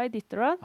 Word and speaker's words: Iditarod: Er det IT, e Itadrod? Hvordan er det du uttaler det? – Det heter Iditarod.